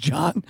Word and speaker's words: Joan: It's spelled Joan, like Joan of Arc, but it's pronounced John.